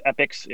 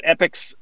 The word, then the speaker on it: EPICX: It's called, I always pronounce it as Epix. It's spelled EPICX, obviously the ICX for the token.